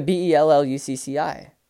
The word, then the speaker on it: Bellucci: Yeah, Bellucci.